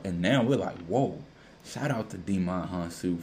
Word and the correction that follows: Djimon Hounsou: it's Djimon Hounsou. Djimon Hounsou.